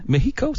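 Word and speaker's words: México: Comment on prononce Mexico à la Mexique? Mexico, c'est ça? – Mexico.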